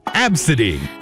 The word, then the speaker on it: Abcde: And then the name spelled A B C D E. Apparently, it's pronounced Absidy.